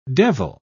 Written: devil

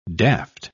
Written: daft

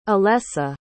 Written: alessa

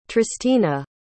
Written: tristina

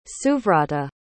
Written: suvrata

